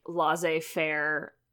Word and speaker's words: laissez-faire: But I think that I am sort of taking this more like laissez-faire. Did I say that right? – That's how I pronounce it.